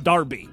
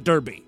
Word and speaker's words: Derbyshire: I guess, you know, it's spelled D-E-R-B-Y-S-H-I-R-E. But that's you're supposed to pronounce that Darby, not Derby. And it's probably sure, Not Shire.